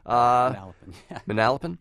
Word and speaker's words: Manalapan: Is it Manalapan, New Jersey? – Manalapan.